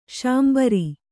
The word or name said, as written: ಶಾಂಬರ